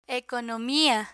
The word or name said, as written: economia